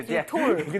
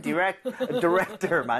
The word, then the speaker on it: director: 디렉토리드다, 디렉토르. – 근데 발음은 디렉터 맞는데 너무 토어는 아니고요.